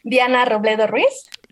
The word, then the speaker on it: Diana Robledo Reyes: Diana Robledo Reyes.